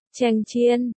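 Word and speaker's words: chen chiên (强箭): Cường tiễn, từ, cường tiễn, đọc là, chen chiên, hải âm hoàn toàn với, cưỡng gian,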